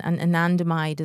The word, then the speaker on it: anandamide: So there, how do you pronounce it? – Anandamide. So I think ananda isn't it Sanskrit? It means bliss. So anandamide is the bliss chemical.